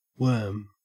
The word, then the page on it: worm: Australian English